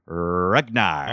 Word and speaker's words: Ruggnar: R-U-G-G-N-A-R. Ragnar.